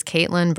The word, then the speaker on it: Caitlin: So my name is Caitlin Bird. It's Caitlin with C's and I's and Bird with a Y. C-A-I-T-L-I-N, Bird, B-Y-R-D.